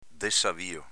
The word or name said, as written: dessavio